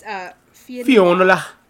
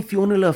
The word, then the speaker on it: Fionnula: It is an Irish name, so I'm going to butcher it, but it's Fionnula. – No, the F I O N N is like Finn. – Oh, they call me Fionnula Flanagan around these streets of...